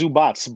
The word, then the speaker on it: Zubac: It's Zubats, but we say Zubac, whatever.